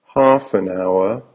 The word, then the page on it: half an hour: American English